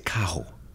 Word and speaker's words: carro: And, you know, the Puerto Rican dialect is very fascinating because you guys don't roll your R's. So when you guys say carro, you guys say cajo, right? – How do you say carro, baby?